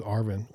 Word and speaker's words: Arvin: And so he wants to, his name's Arvin, which rhymes with, or like RV, Arvin.